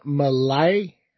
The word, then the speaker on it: Milat: So Dahmer's going up against Ivan Malay. I believe M-I-L-A-T, but I'm assuming because it's kind of foreign, the T is silent.